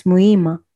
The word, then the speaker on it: Muhima: Yeah, you don't pronounce the H. Not Muhima. It's Muhima.